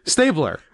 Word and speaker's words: Stabler: You said all the right letters, you just pronounced it weird. Stabler.